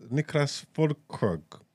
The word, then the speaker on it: Niklas Füllkrug: How do I say this? Niklas Füllkrug. – Yep. – Well, Niklas Füllkrug gave Germany a precious point against Spain after they fight for World Cup survival.